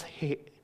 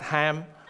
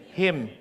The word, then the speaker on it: hem: How do you pronounce hem? We pronounce Filipino hem. Not ham. That's for Christmas. Hem. For Kiwi, call it what? Him. There you go. H-I-M. They just imagine the E, but it's actually I.